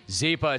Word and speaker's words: Zyppah: Zeepa is spelled Z-Y-P-P-A-H. It's Happy Z spelled backwards. If you go to bed with Zeepa, you wake up with Happy Z's.